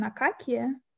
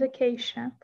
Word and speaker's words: acacia: And you have two different pronunciation, akakia and acacia, for the same word. One is ecclesiastic, one is the traditional.